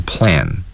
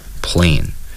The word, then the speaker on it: plan: plan to do something， plan，大家一定要注意 plan 这个单词的发音是读成 plan 而不是 plane，所以有的同学容易在这个发音出问题。